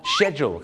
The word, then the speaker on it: schedule: บ้านเราชอบออกเสียง schedule schedule นะฮะชาวอังกฤษนี่นะครับจะออกเสียงไม่เหมือนอเมริกาครับอเมริกาจะคล้ายๆของคนไทยครับก็คือ schedule ครับแต่ปรากฏว่าชาวอังกฤษหลายคนมากนะครับก็จะออกเสียงว่า schedule ครับผมชอช้างนะฮะ schedule นะฮะคล้ายๆชอช้าง she นะฮะ she schedule นะ